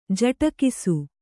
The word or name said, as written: ಜಟಕಿಸು